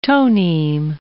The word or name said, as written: toneme